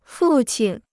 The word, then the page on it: 父亲: fù qīn